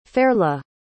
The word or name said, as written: Fairleigh